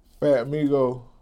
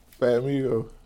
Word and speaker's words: Fat Migo: Fat Migo. – Do people mispronounce this? – Hell no. Plenty, it's simple. Fat Migo.